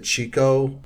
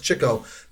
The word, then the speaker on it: Chico: Yeah, I don't know why that is, that everybody pronounces it Chico. Maybe it's because of Chico and the Man. No, it's Chico.